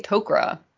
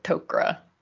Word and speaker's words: Tok'ra: I think I say Tok'ra. Like okra, but Tok'ra, because I'm a lazy American for the most part.